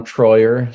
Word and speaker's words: Treuer: My name's Anton Troyer. So A-N-T-O-N. Last name is spelled T-R-E-U-E-R.